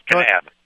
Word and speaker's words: Kanab: This is in, am I pronouncing this right, Kanab, Utah? – Yes, Kanab.